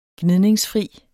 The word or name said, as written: gnidningsfri